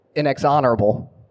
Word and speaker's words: inexorable: Pronounce this word for me. – Inexorable. – That's not how you pronounce inexorable.